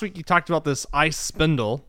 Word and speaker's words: iSpindel: How do you pronounce that? – It's ice spindle. – Okay, ice spindle. It's I guess in German they spell it S-P-I-N-D-E-L. So I always call it the spindel.